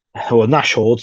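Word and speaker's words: Nashorn: Unfortunately, the Nordhound got actually, Nashord, sorry, I should say correctly, to pronounce it correctly.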